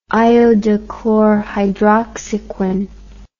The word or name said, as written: iodochlorhydroxyquin